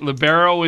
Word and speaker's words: libero: Billy Weaver, outside hitter, and Dave Wynn, uh, who's libero. Did I say that right? All right, libero in the uh, known as the back row, your defensive specialist.